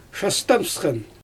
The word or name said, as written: ʃastɛnpsxɛn